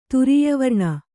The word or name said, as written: ತುರೀಯವರ್ಣ